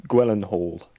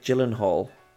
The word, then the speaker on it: Gyllenhaal: Jake Gyllenhaal.